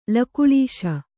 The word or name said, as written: ಲಕುಳೀಶ